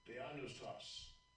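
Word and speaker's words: Theanoustos (θεόπνευστος): It's going to show you how to pronounce the word in the Greek. This T right there. Bam, touch it. Get ready. – Strong's G 2315. Theanoustos.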